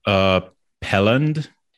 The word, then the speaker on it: Pelland: Pelland.